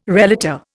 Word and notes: relator: Indian

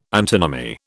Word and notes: antinomy: British English

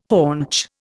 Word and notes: haunch: Indian

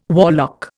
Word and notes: warlock: Indian

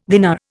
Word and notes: dinar: Indian

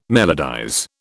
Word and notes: melodize: British English

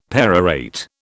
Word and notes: perorate: British English

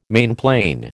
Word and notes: mainplane: American English

